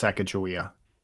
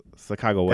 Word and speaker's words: Sacagawea: How you actually pronounce Sacagawea. – Oh yes, Sacagawea. – That's how they would. That's how they would have pronounced it.